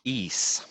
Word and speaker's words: Ys: And is, is, how do you pronounce this? Ease. Ease. Ys.